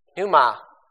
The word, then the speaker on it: pneuma: Pneuma. Sometimes you'll hear people pronounce the P. Sometimes they'll just bypass that because in English we would say something like pneumatic.